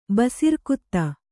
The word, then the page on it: ಬಸಿರ್ಕುತ್ತ: basirkutta; kannada